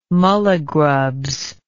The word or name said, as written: mulligrubs